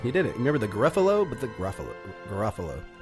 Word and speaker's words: Garofalo: Garofalo.